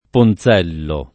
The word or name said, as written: Ponzello